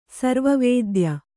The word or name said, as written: ಸರ್ವವೇದ್ಯ